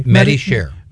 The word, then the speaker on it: MediShare: S-H-A-R-E. – MediShare.